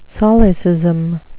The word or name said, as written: solecism